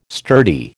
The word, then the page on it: sturdy: American English